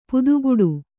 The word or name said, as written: ಪದಗುಡು